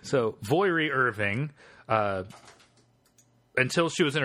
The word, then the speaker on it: Voirrey: V-O-I-R-R-E-Y. – That sounds as good a pronunciation as any. – Yeah, I'm going to go with Voiri, like Moira.